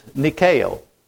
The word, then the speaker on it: Nike: But it's nikeo.